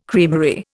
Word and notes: creamery: Indian

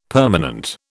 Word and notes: permanent: British English

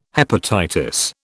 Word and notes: hepatitis: British English

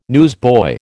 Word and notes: newsboy: American English

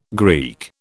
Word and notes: Greek: British English

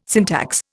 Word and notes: syntax: Indian

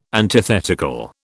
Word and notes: antithetical: British English

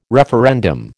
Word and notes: referendum: American English

